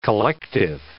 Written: collective

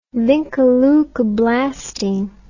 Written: vincaleukoblastine

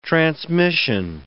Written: transmission

